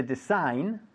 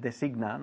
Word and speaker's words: design: Bien, uh, to design, uh -huh, to design, diseñar, muy bien, very easy, with a, a silent G, because you said designa, ¿no?